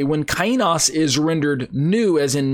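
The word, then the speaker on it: kainos (καινός): He used the word kainos or kainos, if you want to, kainos, however you want to pronounce the Greek, uh, however you were trained and studied Greek. I pronounce it kainos. Uh, that's how I w- that's how I learned it.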